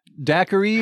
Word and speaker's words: daiquiri: Daiquiri is a family of... – Die economy. – Yeah. – It is originally in... it's from Cuba, where the drink originated. It's daiquiri would be the original pronunciation.